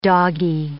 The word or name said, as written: doggie